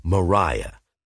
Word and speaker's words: Mariah: Anyway, she says she's Mariah, to rhyme with ire, and a personality to match from the look of her.